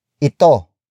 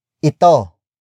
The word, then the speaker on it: ito: The next word is ito. Ito. Ito. Ito is spelled as I T O.